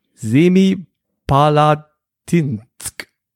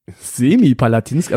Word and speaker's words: Semipalatinsk: Semipalatinsk. – Korrekt. Semi-Palatinsk, also nicht ganz, sondern nur semi, oder wie? Ja, das ist, ähm, also die Betonungen liegen schon mal nicht richtig.